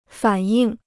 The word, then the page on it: 反应: fǎn yìng